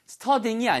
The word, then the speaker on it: studying: 발음 주의하세요. study가 이미 이 발음으로 끝나기 때문에 studying이 아니고요. study 그다음에 ing이 하나 더 붙여요. 그래서 studying 이렇게 이 발음이 두번 연속되는 것처럼 발음하셔야 알아듣습니다.